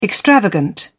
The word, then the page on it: extravagant: British English